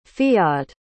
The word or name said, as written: fíadh